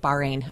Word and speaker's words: Bahrain: Did I say that right? – Bahrain. Uh-huh.